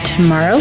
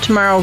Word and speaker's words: Tomaro: I am your host, Deb Tomorrow. Look for me on Facebook, Deb Tomorrow Realtor, T-O-M-A-R-O is how you spell it.